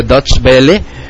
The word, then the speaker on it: Deutsche Welle: Por ejemplo, me llamó la atención y quería comentar con todos ustedes que el medio alemán de radiodifusión Deutsche Welle, se pronuncia Deutsche Welle.